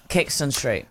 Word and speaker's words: Caxton Street: Caxton Street.